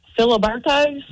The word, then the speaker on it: Filiberto's: How do you say Filiberto's? – How do I say what? – Filiberto's. – What is that? Filiberto's? – It's a Mexican restaurant. Wait, go ahead. Say it again. – Filiberto's? – There you go.